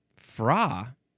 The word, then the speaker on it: Fra: Now we have fra. I think that's the proper way to say it. Fra. Capital F-R-A.